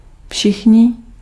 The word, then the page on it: all: /ˈɔl/; /ˈɔːl/; [ɑɫ]; /ˈɑl/